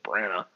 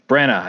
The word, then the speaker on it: Branagh: I never said his name right. I always thought it was just Branagh. – Yeah, Branagh, I think is correct.